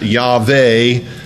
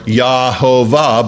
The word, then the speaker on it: Yahweh: We take the Y and put an A after it, and after the V, put an EH, expand it to that, Yahweh, or Yahovah, but it's from the YHVH.